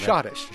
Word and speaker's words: Schottische: I hope I'm pronouncing Schottische.